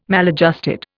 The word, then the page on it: maladjusted: Indian